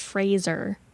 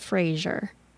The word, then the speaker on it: Fraser: We said it wrong in the first half. Already have a totes regret. It's pronounced Fraser, not Fraser.